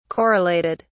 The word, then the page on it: correlated: British English